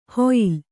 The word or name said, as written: ಹೊಯ್ಯಲು